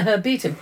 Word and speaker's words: herbetum: It is a herbetum. Well, you've heard of an arboretum.